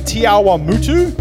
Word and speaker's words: Te Awamutu: We had Pastor Michael and Jillian at Zion Church this morning, which is another one of our Acts churches in Te Awamutu. Have I said that right, eh? Yeah. Yes.